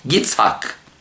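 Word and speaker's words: Yitzhak (יצחק): The Hebrew translation or the pronunciation of Ishaq is Yitzhak.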